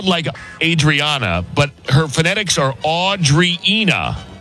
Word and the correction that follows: Andreina: Is that right? Are you Audrina? Is that right? – It's Andreina. – Andreina. – Oh, close. – Andreina.